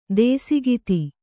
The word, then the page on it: ದೇಸಿಗಿತಿ: dēsigiti